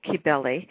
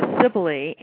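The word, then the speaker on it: Cybele: And am I saying Kabili right? I've heard it said so many different ways. – Well, the Greeks certainly would have said Kabili. It's been anglicized to Sibili, and I think that any pronunciation is fine.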